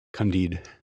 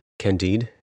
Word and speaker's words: Candide: So I stumbled upon a book in the bookstore, Candide or Candide. I don't know how to say it. – Candide. – Candide.